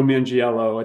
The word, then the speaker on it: Manganiello: So Samuel Jackson and Joe Manganiello. I think I'm pronouncing his name right. – That sounds right.